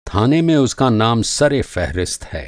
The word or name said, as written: फ़हरिस्त